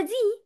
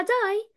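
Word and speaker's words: die: يعني مثلا قلت لكم كلمه داي اللي هي يموت، داي يموت، فكلمه داي يموت احنا مش بنقولها دي بنقولها داي، فاحنا عارفين انها لونج اي، فمش هنتلخبط يعني في النقطة دي.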